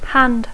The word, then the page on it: hand: English